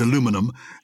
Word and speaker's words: aluminum: So it's spelt like magnesium and it's pronounced aluminium in the UK. Yes. But in America, it's aluminum and it's spelt like platinum.